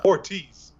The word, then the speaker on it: Ortiz: 2B of Shane and Horitz. But if you were John Mara. – Ortiz. – Yeah, I butchered that.